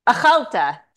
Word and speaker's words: Achalta: Malta. – Achalta, sorry, the emphasis is on the al, a nonpartisan think tank, advancing social cohesion and national resilience in Israel.